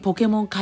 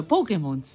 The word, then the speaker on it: ポケモン: イタリア語だと、イタリア語ではないですけど、イタリア人が言うとポケモンです。 – ポケモンって。ポケモンです。イタリア語って必ずどこかにね、アクセントがあるんで、はい、ポケモンって言えないんです。